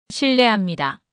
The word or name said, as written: 실례합니다